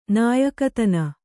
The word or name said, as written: ನಾಯಿಕೆತನ